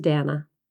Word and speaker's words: Dana: My real name is Dana, rhymes with banana.